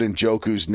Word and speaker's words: Njoku: Number one, I guess I've been saying David Njoku's name wrong for years because it was Njoku all night, and for some reason, when Joe Buck said it, it registered with me.